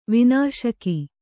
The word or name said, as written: ವನಶಿಖಿ